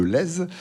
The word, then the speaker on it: Lez: On prononce bien le Z, hein, je crois. – Tout à fait. – On dit bien le Lez.